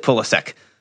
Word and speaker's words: Pulisic: He pronounces it Pulisic.